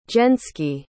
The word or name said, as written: jenski